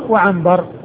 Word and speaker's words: عنبر: وعنبر. فعبثر بن القاسم هو بالعين والباء والثاء والراء، وعنبر الذي هو جد محمد بن سواء هو بالعين والنون والباء والراء،